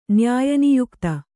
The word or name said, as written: ನ್ಯಾಯನಿಯುಕ್ತ